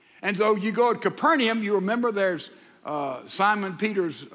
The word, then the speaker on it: Capernaum: We'd made our way with a busload of church members up to Capernaum, Capernaum as they pronounce it.